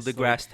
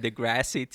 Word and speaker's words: deGrasse: I think it's funny he said Degrassi too, and it's just Degrasse.